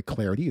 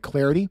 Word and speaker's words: Clardy: At Troy Clarity is the way to go there. Last name is spelled C-L-A-R-D-Y, at Troy Clarity.